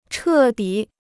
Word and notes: 彻底: chè dǐ

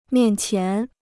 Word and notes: 面前: miàn qián

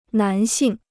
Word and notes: 男性: nán xìng

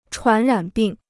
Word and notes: 传染病: chuán rǎn bìng

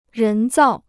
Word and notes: 人造: rén zào

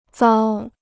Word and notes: 糟: zāo

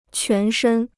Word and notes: 全身: quán shēn

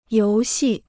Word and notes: 游戏: yóu xì

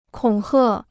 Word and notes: 恐吓: kǒng hè

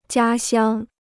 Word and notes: 家乡: jiā xiāng

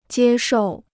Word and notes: 接受: jiē shòu

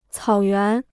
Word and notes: 草原: cǎo yuán

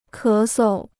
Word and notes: 咳嗽: ké sou

